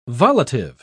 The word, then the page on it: volitive: British English